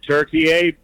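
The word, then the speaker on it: Türkiye: The Turks have always called it Turkey A, but in English, the pronunciation was like the bird.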